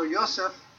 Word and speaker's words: Yosef: And verse 4, "So Yosef," and I'm going to be using Hebrew names here, Yosef is Joseph,